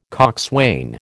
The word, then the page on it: coxswain: American English